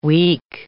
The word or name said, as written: week